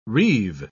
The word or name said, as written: reeve